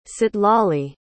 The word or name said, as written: citlali